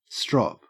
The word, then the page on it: strop: Australian English